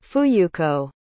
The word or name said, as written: fuyuko